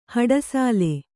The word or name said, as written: ಹಡಸಲೆ